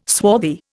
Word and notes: swarthy: Indian

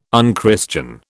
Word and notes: unchristian: British English

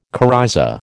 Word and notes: coryza: American English

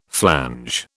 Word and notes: flange: British English

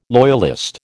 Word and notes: loyalist: American English

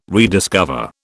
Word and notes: rediscover: British English

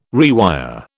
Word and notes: rewire: British English

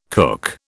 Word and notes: kook: British English

